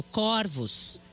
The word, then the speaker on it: corvos: Estranhei que a palavra corvos, foi pronunciada com o O fechado. Olha lá, igual eu. Pesquisei e descobri que, diferentemente do singular, no plural, a palavra corvos é pronunciada com o O aberto, corvos.